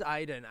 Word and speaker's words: Aidan: My name is Aiden, A-I-D-A-N,